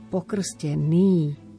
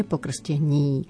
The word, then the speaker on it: pokrstený: Pozor, v prípade slova pokrstený nemôžeme v žiadnom prípade zmekčiť. Pokrstený, nie pokrstení. Takisto slobodného, nie slobodného.